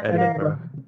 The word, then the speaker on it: Edinburgh: I'm going to totally butcher it, but I was in Edinburgh. – Yeah, Edinburgh.